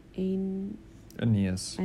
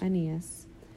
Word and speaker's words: Aeneas: How do you say this name? – Aeneas. – Aeneas.